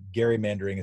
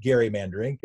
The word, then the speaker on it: gerrymandering: I also pronounce it gerrymandering instead of gerrymandering.